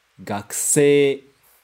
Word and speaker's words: gakusei: O C termina com E e depois tem um I. Mas a gente não vai pronunciar I, vai só esticar o E. Gakusei.